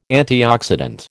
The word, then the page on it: antioxidant: American English